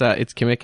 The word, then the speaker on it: Kimmich: It's Kimmich, actually. It's a hard K sound at the end.